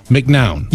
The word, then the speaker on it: McNown: You know, I always say, because it's M-C-N-O-W-N, McNown. – Yes. So that is how I pronounce it and have pronounced it for my whole life. But I will say, I think the accurate pronunciation from, you know, my ancestry is probably McNown.